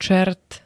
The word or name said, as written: čert